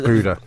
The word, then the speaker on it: Budda: To find out his first name's Budda, gotta love it. – Well, like Budda. – So good. Sorry, pronounced Buddha.